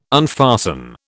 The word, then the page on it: unfasten: British English